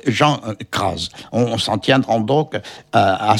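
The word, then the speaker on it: Cras: Et ce nom se prononce crase, avec un Z, SZ, nous précise Michel Fleury, musicologue.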